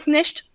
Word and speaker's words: Knecht: Dalton Knecht. – Comment ça Knecht. – s'écrit? Knecht. – Knecht.